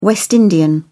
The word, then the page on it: West Indian: British English